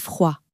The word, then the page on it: froid: French